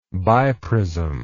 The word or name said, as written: biprism